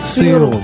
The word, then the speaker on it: Suyru: Suiru. – Oui. – J'aime bien prononcer. – S-U-Y-R-U. Suiru. – Suiru.